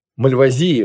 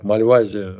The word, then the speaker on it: Мальвазия: Мальвазии, вообще-то, правильно говорить. Я уж так Мальвазия по-деревенски говорю.